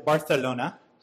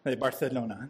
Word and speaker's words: Barcelona: You see, he lived most of his life in Barcelona, or if you've been there, you know they pronounce it Barcelona. Barcelona. Spanish, right, Nancy? Sounds different than the Spanish from South America and such. But Barcelona in the 60s had this massive flood.